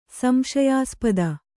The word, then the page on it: ಸಂಶಯಾಸ್ಪದ: Kannada